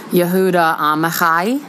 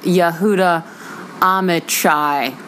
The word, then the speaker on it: Yehuda Amichai: I said that properly, which made me feel a little bit weird about myself. Yehuda, it looks like Yehuda Amichai, like you're going to go get an Oprah chai tea latte.